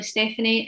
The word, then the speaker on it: Stefanie: And that is Naturally Stefanie.